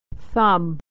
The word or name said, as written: thumb